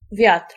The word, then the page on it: wind: /ˈwɪnd/; /ˈwaɪnd/